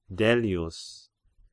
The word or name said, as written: Dellius